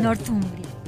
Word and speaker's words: Northumbria: Northumbria. ¿Se dice así? ¿No? – Northumbria.